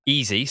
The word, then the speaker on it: Easee: Easy, so EASEE is the way it's spelled, are a Nordic company.